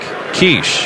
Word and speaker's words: Kirsch: By the way, Brandon's last name is spelled K-I-R-S-C-H. But we were informed this morning when we got here it is pronounced Kirsch. Like Kirsch. – Yeah, K-E-E-R. Kirsch.